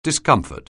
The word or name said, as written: discomfort